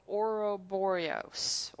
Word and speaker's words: Ouroboros: I'm going to probably butcher the name. It's called Ouroboros. Ouroboros? Ouroboros. – Ouroboros. – Ouroboros. – Ouroboros. – Ouroboros. – Yes, thank you.